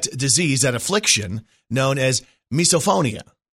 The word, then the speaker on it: Misophonia: Misophonia.